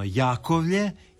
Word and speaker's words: Jakovlje: Ne, jedino je pravilno Jakovlje i Demerje. Pitajte ljude tamo i kad god možemo, onda to prihvaćamo i izgovaramo tako.